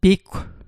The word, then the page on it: piggw: Mi'gmaq